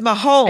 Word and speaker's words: Mahomes: It's Mahomes.